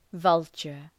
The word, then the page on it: vulture: ‘vʌltʃər